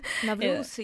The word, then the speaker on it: Novruz: This week we'll provide you with some interesting insights into Novruz. I probably pronounced it wrong. – Novruz, yeah. – Novruz.